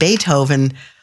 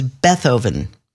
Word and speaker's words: Beethoven: And if you find yourself in St. Louis, you might live on a street that you'd assume would be pronounced Beethoven, but is Beethoven.